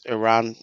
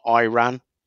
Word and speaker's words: Iran: First of all, you pronounce my country's wrong. It's Iran, not Iran. Have you seen this? – No. – Like official press conference. And yeah, he's like, you Americans, you say our country wrong.